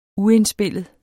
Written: uindspillet

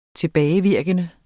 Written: tilbagevirkende

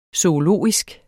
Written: zoologisk

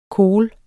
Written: kohl

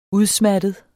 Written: udsmattet

